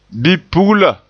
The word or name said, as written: bipugla